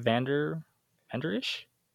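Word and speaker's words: Vander Esch: I can't say his last name. Leighton Vanderish? – Yeah, that's how you say it, I'm pretty sure.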